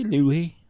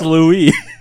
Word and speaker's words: Louis: If we were in France, maybe Louis, but... – I'll just say Louis. – This is Arkansas. – Yeah. He was... – We pronounce our S's here in Arkansas. It's Louis.